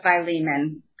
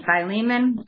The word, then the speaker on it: Philemon: But anyway, we open the book of Philemon today. That's how I'm going to pronounce it. I know I'm sure you've heard many other variations on the pronunciation of this book, but I will be saying Philemon.